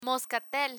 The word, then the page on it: moscatel: Spanish